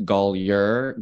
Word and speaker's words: Gallier: Unfortunately, my last name, it's spelled as if it should be rendered Gallagher, but it's actually pronounced Gallier. Gall as in golf, yer as in yurt. So yeah. Daniel Gallier.